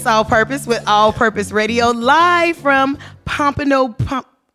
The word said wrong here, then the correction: Pompano Beach: Pompano Beach. – Pompano Beach.